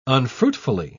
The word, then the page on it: unfruitfully: British English